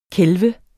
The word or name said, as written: kælve